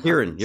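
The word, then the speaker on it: Ciarán: Kieran, you're right. It's Kieran.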